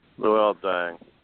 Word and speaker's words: Luol Deng: Luol Deng.